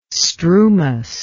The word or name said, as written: strumous